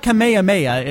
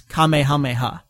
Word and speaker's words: Kamehameha: It's not Kamehameha, it's Kamehameha. – Yes. So the, I guess you could say the, well, let's see, the accent is on a different syllable.